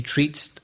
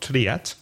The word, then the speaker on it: treat: And be careful of the pronunciation, because the spelling is T-R-E-A-T, but the vowel sound is E. So it's treat, not triat.